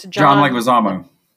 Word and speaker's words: Leguizamo: Did you know that the one-eyed guy is, I can never pronounce his last name, but it's John Leguizamo.